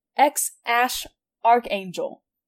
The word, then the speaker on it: X Æ A-12: The most prominent explanation that I've seen is people think the baby's name is pronounced X-Ash Archangel. X-Ash Archangel. X for the X. And then the symbol that they use for the AE is a ligature pronounced ash.